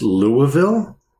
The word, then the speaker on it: Louisville: Did you know it's pronounced Louisville? – Yeah.